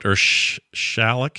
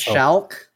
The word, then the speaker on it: Schalck: One from Ben Schlack, who wrote Shalik. Shalik. Does that look right? I think I'm saying that right. – Shalk. – Shalk. – Shalk. – Shalk. – Shalk. – It's like S-C-H-A-L-C-K. Yeah, John's got it.